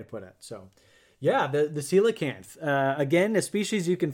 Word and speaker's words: coelacanth: So, coelacanth.